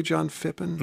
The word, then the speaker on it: John Phippen: One of them was John Phippen. Brian Mann of North Country Public Radio, am I pronouncing his name correctly, John Phippen? – That's right, John Phippen, a transplant from New York to California.